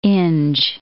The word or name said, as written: Inge